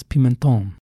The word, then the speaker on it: Pimentón: Am I saying that right? – It sounded beautiful. – Yes, Pimentón.